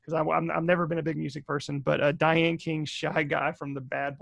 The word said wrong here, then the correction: Diana King: I might have said that name wrong. Hold on one sec. Not Diane King. Yeah, Diane King. Diana King.